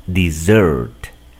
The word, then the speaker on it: dessert: Por esa razón debes de aprenderte que se escribe con dos S's y también el acento recae en la segunda sílaba. Así como el postre es nuestra segunda comida, también aquí el acento recae en la segunda sílaba. Repite conmigo. Dessert. Dessert. Acá, en cambio, desierto lleva solo una S y el acento recae en la primera sílaba.